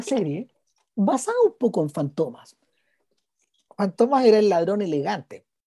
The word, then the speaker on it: Fantomas: Eh, y, y Fantomas, en realidad, así se pronuncia, Fantomas eh,